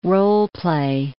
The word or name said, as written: role-play